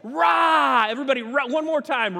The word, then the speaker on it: Ra: Everybody say rah.